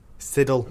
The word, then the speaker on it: Siddle: Sidler. – So close. Siddle.